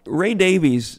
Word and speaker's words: Davies: By the way, Ray Davies, that's the way most people pronounce it.